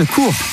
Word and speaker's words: Koor: Toutes les plus belles innovations ont une histoire à part, et c'est le cas de Cours. Ça s'écrit K-O-O-R, un emballage inventé par un Rouennais et dont l'histoire commence par un yaourt.